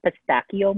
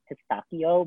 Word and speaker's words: pistachio: Annoy people when you say pistachio, because that's actually like the true Italian way to pronounce it.